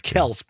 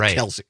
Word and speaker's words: Kelce: It looks like Kels, but he pronounces it Kelce.